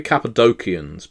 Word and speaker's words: Cappadocians: It should be Cappadocians, by the way. I don't believe the Cappadocia had the soft C sound. So, when it's transliterated into Cappadocian or Cappadocia, it should actually be Cappadocia and Cappadocians and Cappadocius. There's your little lesson for today.